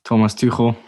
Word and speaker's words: Thomas Tuchel: Thomas Tuchel.